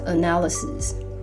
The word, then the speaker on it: analysis: Number five，分析动词，analyze，analyze，名词的分析，发音要转一下，变成 analysis，analysis。所以比如说成本分析，cost analysis。